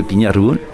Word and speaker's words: Pignaroul: Peut-être que je le prononce mal. – Non, c'est le Pignaroul.